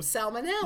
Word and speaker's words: salmonella: Okay, salmon is not with an L. How do you pronounce the bacterial disease? S-A-L-M. – We talked about this once. That's the problem. Salmonella. – Yeah, salmonella is how I would pronounce it.